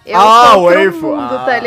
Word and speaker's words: waifu: A última sílaba é a sílaba tônica, é tipo, é waifu.